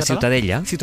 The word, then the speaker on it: Ciutadella: ¿cómo se pronuncia? – La ciutadella.